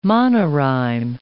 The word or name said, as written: monorhyme